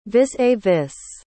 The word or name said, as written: vis-à-vis